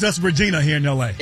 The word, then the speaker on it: Regina: It's Regina. That's the way it's pronounced.